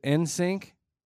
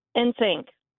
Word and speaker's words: NSYNC: Is it NSYNC? – NSYNC. – NSYNC. – Without the I. Without the I. NSYNC.